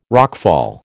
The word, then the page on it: rockfall: American English